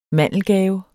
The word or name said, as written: mandelgave